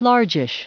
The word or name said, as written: largish